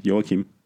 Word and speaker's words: Joachim: Yeah, Joachim. – Like, not Joachim, it's not Joachim? – No, that's I looked it up.